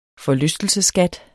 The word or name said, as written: forlystelsesskat